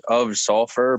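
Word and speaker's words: Ov Sulfur: another one I've gotten into recently is Of Sulfur, but Of is spelled OV.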